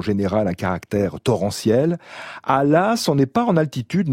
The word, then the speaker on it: Laàs: Il y a 130 euh, habitants environ à Las.